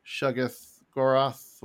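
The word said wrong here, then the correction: Shuma-Gorath: whatever, however you say his name. Shuggoroth, whatever. – Shumagoroth, there it is.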